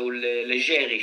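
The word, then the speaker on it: Leggeri: Fabrice Legeri or Legeri, because I think his ancestries are Italian. So Legeri should be the right pronunciation.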